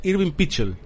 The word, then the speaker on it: Irving Pichel: Irving Pichel, ¿se puede pronunciar? Irving Pichel. – Irving Pichel. Perfecto.